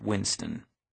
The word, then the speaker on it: W1N5T0N: My name is Marcus Yallo, but back when this story starts, I was going by W1N5T0N, pronounced Winston. Not pronounced W1N5T0N, unless you're a clueless disciplinary officer who's far enough behind the curve that you still call the Internet the Information Superhighway.